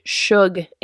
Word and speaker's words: Shug: the other one was one of the main characters is named Shug Avery, like sugar, but it's spelled S-H-U-G.